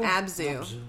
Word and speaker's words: Abzu: If you have not played Abzu. – Abzu? – Abzu.